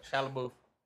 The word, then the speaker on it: Shia LaBeouf: Shia LaBeouf? Yeah, I said that right. – Shia LaBeouf.